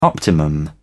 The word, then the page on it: optimum: British English